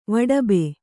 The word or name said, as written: ವಾಡಬ